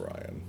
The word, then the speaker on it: Rian: It's spelled weird. – Yeah, but it's pronounced Ryan.